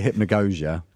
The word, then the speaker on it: hypnagogia: Yeah, so given that Lustmore explored hypnagogia, is that hard G or? – It's one of these things. I say hypnagogia. – Okay, goja. – But a lot of people say it differently.